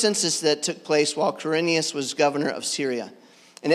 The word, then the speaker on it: Quirinius: And I always like to have someone else read this because I can't pronounce Quirinius. Is that close enough? All right, good. That's correct.